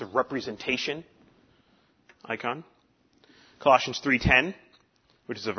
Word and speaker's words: eikon: I- icon. It's spelled in the Greek E I K O N, not I C O N.